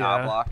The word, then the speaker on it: Knoblauch: Knoblauch.